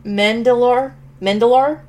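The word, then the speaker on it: Mandylor: Anyway, it stars Tobin Bell as John Kramer in flashbacks, Betsy Russell as Jill Tuck, Kostas Mandalore, I believe is how you pronounce his last name, as Mark Hoffman, Lyric Bent as Daniel Rigg, who we saw in part 2.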